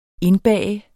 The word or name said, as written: indbage